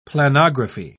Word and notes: planography: British English

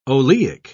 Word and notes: oleic: British English

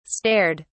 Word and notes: stared: British English

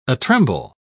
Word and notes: atremble: British English

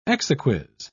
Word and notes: exequies: British English

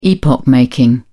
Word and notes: epoch making: British English

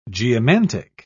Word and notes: geomantic: British English

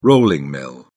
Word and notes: rolling mill: British English

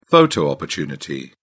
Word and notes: photo opportunity: British English

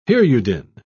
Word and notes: hirudin: British English

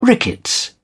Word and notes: rickets: British English

